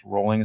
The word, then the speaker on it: Rowling: Rowling, or I guess Rowling is perhaps how it's pronounced, where there's no T, it's Voldemort, where she basically told Cuarón, like, you can change certain things from the books as long as you capture the spirit.